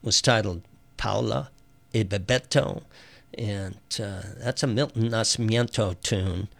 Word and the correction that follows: Nascimento: Nascimento, I should say. No I in that second to last syllable.